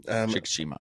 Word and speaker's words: Shikishima: when I hope it's pronounced Koichi or Shikishima. – Shikishima. – If that's his correct saying of his name.